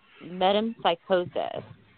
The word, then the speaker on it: metempsychosis: Reincarnation may also be called transmigration or metempsychosis.